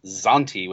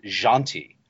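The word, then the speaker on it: Zhanti: For years and years and years, I was pronouncing the elf's name as Zanti with a hard Z. But his name is also spelled Z-H. And I actually heard Wendy pronounce it, and she pronounced it Janti.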